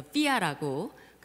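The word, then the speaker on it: FWIA: 그래서 어, 약자로는 FWIA에서 삐아라고 그렇게 발음을 합니다.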